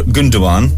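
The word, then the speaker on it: Gündoğan: I think Mr. Gundogan, if I've pronounced it properly. – Yeah, you did.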